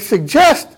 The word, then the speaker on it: suggest: And now we change the pronunciation of the G to J, and we get suggest.